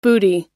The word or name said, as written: bootie